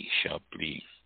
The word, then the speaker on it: Chablis: Chablis, pronounced Chablis as an Shablee.